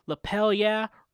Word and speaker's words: Lapel Yeah: And also, today I want to get to our sponsor for the episode, Lapel Yeah, rhymes with hell yeah, as in give me a lapel yeah.